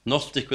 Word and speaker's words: Gnostic: Gnostic with a silent G.